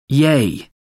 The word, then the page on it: yay: American English